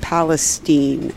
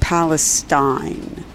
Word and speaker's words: Palestine: Anyway, Cut the Pentagon is with us saying, do you all know why the media is pronouncing East Palestine versus East Palestine? Is it to hide the question of Palestine? I have thought so too, Cut the Pentagon. Actually, I was going to get to that. That there was at first both pronunciations, and I noticed they're now saying Palestine, but I call it Palestine. Maybe because I read it more often than I hear about it. So I'm reading Palestine.